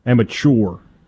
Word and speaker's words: amateur: How do we want to pronounce it, by the way? – Amateur. – Chore. Amateur. – Amateur and amateur are both accepted pronunciations of the word.